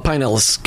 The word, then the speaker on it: Pinellas: So you even say it right, because if you're from here, you call it Pinellas County. Instead of those of us that come here, we just call it Pinellas County.